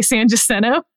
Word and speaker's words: San Jacinto: San what? You pronounce it Jacinto? – Yeah. – Okay, that's interesting. Go ahead. – San Jacinto. – Oh, I've always heard it, but that's just me. – In Houston, we say San Jacinto.